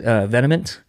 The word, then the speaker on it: Vehement: I pronounce it Vehement, V-E-H-E-M-E-N-T.